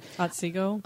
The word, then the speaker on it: Otsego: Hyde Hall overlooks Otsego, I guess, or do you know how to say it? – Otsego.